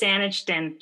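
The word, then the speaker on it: Saanichton: It's Sanichton, just how it's spelled.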